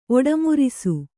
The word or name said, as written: ಒಡಮುರಿಸು